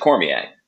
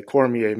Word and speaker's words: Cormier: No, I was going to say that, growing up, I would tell my classmates it's like Mark Messier, Cormier Messier, and that's how they would remember how to pronounce it.